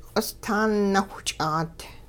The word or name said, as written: q'ostaan nawhch'aat